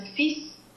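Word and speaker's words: fils: It would be F I S. We pronounce it F I S, and that means sun, someone's sun, not the sun from the sky, which is soleil, but your offspring, fetus.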